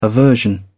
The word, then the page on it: aversion: British English